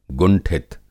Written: गुंठित

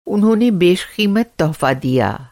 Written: बेशक़ीमत

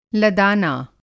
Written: लदाना

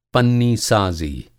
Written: पन्नीसाज़ी

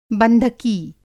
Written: बंधकी

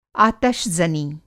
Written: आतशज़नी

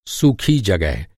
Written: जगह

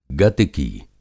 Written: गतिकी

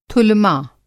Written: थुलमा

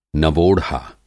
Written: नवोढ़ा

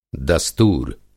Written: दस्तूर

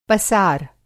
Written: पसार

